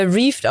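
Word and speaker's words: Reved: Nee, also der Name wird äh, Reeved ausgesprochen. Reeved.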